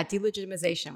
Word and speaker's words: delegitimization: So Alma's mission is actually to fight the delegitimation of Israel, or to fight the delegitimation, I'm saying that correctly? – Yeah, delegitimization, we got you. – Delegitimization, I'll say it again.